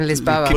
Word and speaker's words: Lisboa: I'm just going to keep saying Lisboa, which is how they say it in Portugal.